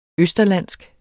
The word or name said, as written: østerlandsk